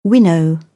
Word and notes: winnow: British English; əʊ

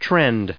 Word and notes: trend: noun

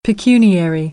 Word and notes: pecuniary: American English

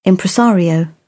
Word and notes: impresario: British English

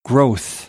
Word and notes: growth: American English; oʊ - əʊ

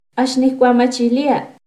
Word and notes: Axniccuamachilia: Náhuatl

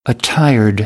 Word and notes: attired: American English

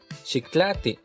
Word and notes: xiktlaati: Náhuatl

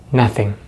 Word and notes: nothing: American English

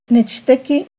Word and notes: Nechtequi: Náhuatl

